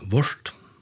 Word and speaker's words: Waurd: Och stort lite är att agotiskon var det Waurd, W-A-U-R-D, men A-U uttales som Å, så her var det Waurd.